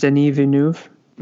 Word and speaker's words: Villeneuve: Denis Denis Villeneuve. Yeah, um, I think um, that's how it's pronounced.